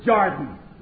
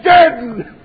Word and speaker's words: Jordan: They pronounce it Jordan. No timer ever said Jordan in his life. Never. They said Jordan.